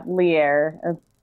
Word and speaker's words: Lierre: Yeah, it's like, Lierre. I get leery sometimes. The very first time I was ever introduced on anything, it was leery.